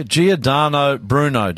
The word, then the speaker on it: Giordano Bruno: When I was looking at this guy, Giordano Bruno, did I say that right? – Yeah, that's fine.